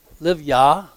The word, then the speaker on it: Livyah: Livyah would be the pronunciation on that, or the root idea behind it.